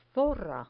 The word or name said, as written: zorra